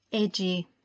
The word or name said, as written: Eiji